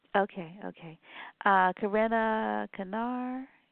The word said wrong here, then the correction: Karina Kanin: if I'm pronouncing correctly, and others. – Karina Kanin. Yeah. – Oh, Karina Kanin.